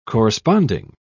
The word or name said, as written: corresponding